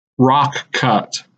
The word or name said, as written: Rock Cut